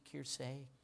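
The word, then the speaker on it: Kersee: And I think Jackie Joyner Kiersey, am I pronouncing that right, Levi? Kiersey, Kiersey? All right, thanks.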